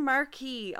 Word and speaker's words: Marquis: That Marquis. Actually sounds like a brand of marquees. Yeah. Like a marquee. Yeah, exactly. – Marquee is an M-A-R-Q-U-E-E. – Q-U-I-S. – How did she spell it? – M-A-R-Q-U-I-S. – Oh, it's French.